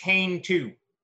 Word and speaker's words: Canto: Got a lot of Jim, it's pronounced Kane 2. – Kane. All right. – That's that so, Kane 2 comic.